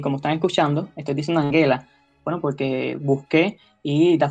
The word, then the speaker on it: Angela: en alemán esa G se pronuncia no como la de gestión, sino más bien como la de gato. Por eso, lo más cercano al alemán sería decir Angela Merkel.